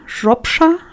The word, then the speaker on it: Shropshire: Und dann gab es bei Frau Wölfchen noch, jetzt kann ich es nur falsch aussprechen, ein Knäuel Shropram. Shropshire heißt es so. Das ist dieses Shire, was manchmal ausgesprochen wird und manchmal nicht.